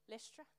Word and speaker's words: Lystra: And then he went to Lystra? Yeah, Lystra, okay.